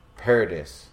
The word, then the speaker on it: Paradis: I really think that I can't wait for Paradis. – Yeah. – It's Paradise, but without the E. – Yeah. – So I must pronounce it Paradis, I guess.